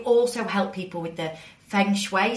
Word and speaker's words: Feng Shui: To be honest, that's probably the most difficult part of Feng Shui, really, just pronouncing it. But I pronounce it as Feng Shui. Lots of people pronounce it differently, but that's, yeah, just go with that.